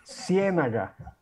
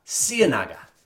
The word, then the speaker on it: Ciénaga: And from Monteria, we, if you'll advance it one more time, we went out to a small town by car uh, called Ciénaga. Did I say that correctly? Ciénaga. Close. Tell me. Let's let's let's have it the Colombian way. – Ciénaga. – Ciénaga. – You got it.